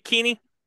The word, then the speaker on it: Kinne: It's funny because the way everybody says it, it sounds like Cartman talking to his friend Kenny, Kenny, GJ Kinne.